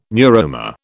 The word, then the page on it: neuroma: British English